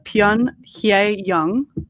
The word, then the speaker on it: Pyun Hye Young: It's by Pyun Hye Young. So it's spelled P-Y-U-N-H-Y-E Young, like you would spell young.